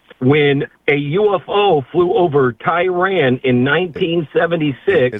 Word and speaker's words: Tehran: It's Tehran. Tehran. As President Carter said to me, Tehran. That's how he pronounced it.